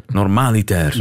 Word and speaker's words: normaliter: Maar je ziet daar weer die druk van die voorlaatste lettergreep die wij zo vaak beklemtonen in het Nederlands. En dus wordt dat ineens niet meer dat in ons gevoel vreemde, hè, normaliter.